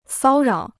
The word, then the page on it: 骚扰: sāo rǎo